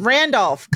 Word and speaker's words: Randolph: But Divine Joy Rudolph, she just really, let me not say her name wrong before I, like, is it not Rudolph? It's Randolph. God, I knew that was stupid. Randolph.